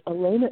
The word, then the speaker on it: Alena: Elena is spelled A-L-E-N-A.